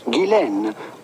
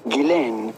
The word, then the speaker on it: Ghislaine: And in French, it can be pronounced either Guylaine or Ghislaine. But in British, as is the case for her, her first name is generally pronounced Guylaine Maxwell.